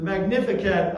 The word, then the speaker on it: Magnificat: The Magnificat, I think, is how you pronounce it. The word is, it's the Latin word for magnify, and it comes from Mary's opening line to the hymn.